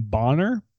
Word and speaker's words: Bonner: Basketball players don't like boners, if you know what I mean. – I'm not commenting. – I have been told that she pronounces her last name Bonner.